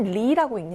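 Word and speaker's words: 리 (梨): 배는 리라고 읽는데요. 헤어지다, 떠나다라고 말하는 그 리와 발음이 같습니다. 리, 예, 특히 이 배를 반으로 갈라서 나누어 먹는다라는 뜻의 펀리는 헤어지다라는 발음, 펀리, 분리하다의 헤어지다죠. 펀리와 발음이 완전히 같아요.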